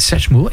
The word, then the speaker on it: Satchmo: Donc c'est une bande dessinée de Léo Hetz aux éditions Jungle Ramdam, Satchmo. – Satchmo. – Alors, ça, j'avais dit, j'arrivais pas à le prononcer. – Satchmo. – Voilà, Satchmo.